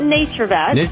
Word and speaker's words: NaturVet: How do you pronounce the company's name? – You pronounce it NaturVet. – NaturVet. It's NaturVet. So there's no E in nature there.